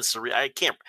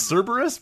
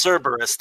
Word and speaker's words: Cerberus: Not even Cerebrus. I'm probably mispronouncing all of these. You know, we're giving people material. – Yeah, Cerberus. I think it's Cerberus, but yeah, that's it's Cerberus, the three-headed dragon-tailed dog that was the guardian of the gates of the underworld.